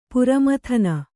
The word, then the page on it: ಪರಮೋದನ: Kannada